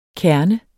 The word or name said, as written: kerne